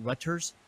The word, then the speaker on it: Reuters: Reuters.